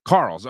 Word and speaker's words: Carrolls: Very, very excited to be joined today, and I probably should have done this before the show, by Carter Carrolls. How do we pronounce the last name? – Close. It's Carrolls. – Carrolls.